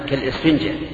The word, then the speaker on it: كالإسفنجة: هذه الوصية لا تجعل قلبك كالسفنجة. أنا أحسبها ألف، أحسبها همزة، كالإسفنجة هكذا ننطق بها.